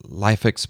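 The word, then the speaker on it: LIFX: I've seen Osram and Lifix bulbs. I don't even know if I'm pronouncing them right. – You are.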